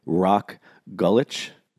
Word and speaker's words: Rok Gulič: Today on the episode, I've got a very special guest, Mr. Rok Gulič.